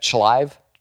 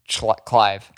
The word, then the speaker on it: Chlive: How would you say that? Chlive? Clive. – Clive. – So this, the H is silent.